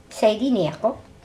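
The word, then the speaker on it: Sadie Neakok: N-E-A-K-O-K. It's a sort of a silent K sound. Like more of a G, between a G and a K. Neacook.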